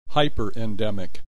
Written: hyperendemic

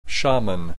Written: shaman

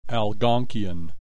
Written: Algonkian

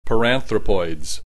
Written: paranthropoids

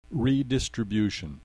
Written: redistribution

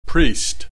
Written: priest

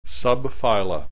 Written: subphyla

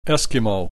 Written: Eskimo

